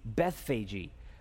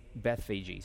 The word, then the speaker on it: Bethphage: But I even Googled it and I listened to the pronunciation and that's how they're pronouncing it. So that's why I'm saying it that way. Also, men that I tremendously respect, like our pastor and Pastor MacArthur, say Bethphage.